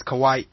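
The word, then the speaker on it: kawaii (Kauaʻi): The thing is, apparently, everybody says Kauai, and I found out the apostrophe in Native Hawaiian is in a letter. So it's you gotta take the pause. It's kawaii because there's an apostrophe there.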